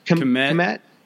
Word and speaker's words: Kmet: Have you heard some of these guys talking about Cole Kmet at the tight end position? Is that how you pronounce that? – Kmet.